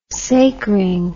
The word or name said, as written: sacring